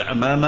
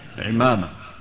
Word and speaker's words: عمامة: إن نطقتها بالفتح فهذا جائز، وإن نطقتها بالكسر فهذا أيضا ها جائز، تقول عمامة أو تقول ها عمامة، كلا التعبيرين صحيح.